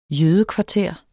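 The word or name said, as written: JØDEKVARTER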